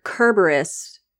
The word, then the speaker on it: Kerberos: So I had heard Kerberos more and more over the last few years because that is the way you pronounce it from the ancient Greek. It's spelled with a K.